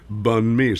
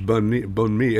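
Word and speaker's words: bánh mì: Pronounced Bun Mi, spelled B-A-N-H-M-I, but it's Bun Mi.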